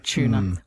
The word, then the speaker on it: tuna: Emphasis on the U, the tuna, the assume. – What we do is merge that U with the T and becomes a ch, tuna.